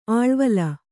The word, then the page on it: ಆಳ್ವೇಲಿ: āḷvēli